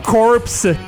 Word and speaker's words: corpse: Et ça continue comme ça, mais donc corpse, C-O-R-P-S-E, core, C-O-R-P-S, on prononce pas le P, horse et worse, où le O est un E.